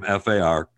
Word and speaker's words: Farquhar: So just tell me your last name. – So I'll preempt you. So it's Farquhar.